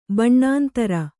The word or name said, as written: ಬಣ್ಣಾಂತರ